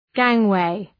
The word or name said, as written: gangway